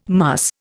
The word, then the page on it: muss: Indian